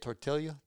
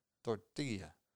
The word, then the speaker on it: tortilla: How do you say it? Tortilla? – Tortilla. – Tortilla. – Yeah. – Say it again. – Tortilla. – Man, you guys roll those L's.